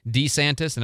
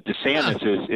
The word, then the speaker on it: DeSantis: which he says DeSantis and everybody else says DeSantis.